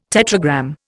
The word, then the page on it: tetragram: Indian